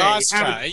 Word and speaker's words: Austra: Astora, Astra, A-U-S-T-R-A. – Astra. Yeah, okay. Your pronunciation threw me there. It's Astra.